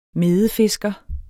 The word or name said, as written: medefisker